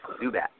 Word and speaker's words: Zubac: The Hawks receive Jeremy Lin from the Nets and Avicii Zubac from the Lakers, as well as, yes, I think it's pronounced Zubac. I don't know how, but I've heard it pronounced that way several times, and it doesn't make any sense to me, but apparently that's how it's pronounced.